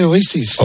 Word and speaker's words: Oasis: ¿Cómo se dice Oasis? – Oasis.